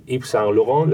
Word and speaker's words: Yves Saint Laurent: Eh, eh, Yves Saint Laurent, ¿lo he dicho bien? – Muy bien.